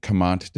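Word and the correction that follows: Comte: Someone pointed out to me that for a year and a half, I've been saying like one of the mainstays of our show. It's C-O-M-T-E.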